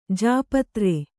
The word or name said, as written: ಜಾಪತ್ರೆ